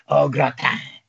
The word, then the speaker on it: au gratin: Potatoes au gratin. Am I saying it right? I sound like Adam Sandler. – Yeah, you sounded like Canteen Boy. Yeah, that's exactly. You said it perfectly.